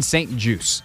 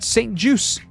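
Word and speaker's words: St-Juste: And that is that his name is said Benjamin St. Juice, as in a juice box, as in apple juice, orange juice. It is French-Canadian. Or Canadian French, more specifically. There's no T pronounced. This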